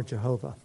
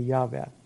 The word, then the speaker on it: Yahweh: so I don't ha- have any hang up, but, but it's still the incorrect pronunciation. The correct one would be Yahweh.